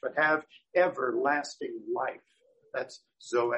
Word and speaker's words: Zoe: zoe, which you will recognize as Z-O-E, okay? Z-O-E in the name Zoe means life, okay? But Zoe, the Greek pronunciation, is life, true life, eternal life, okay?